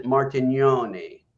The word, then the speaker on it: Martignoni: The G is not pronounced. It's silent. If most Europeans know, immediately pronounce it Martignoni.